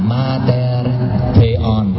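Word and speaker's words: Mater Theon (Μήτηρ Θεοῦ): Cái chữ giống chữ O đó, gạch ngang rồi gạch dọc xuống đó, đó không phải là chữ O mà nó là chữ Theta. Rồi cái chữ kia nó không phải là chữ V mà chữ N, tiếng Hy Lạp chữ N. Đọc là Mater Theon, Mẹ Đức Chúa Trời.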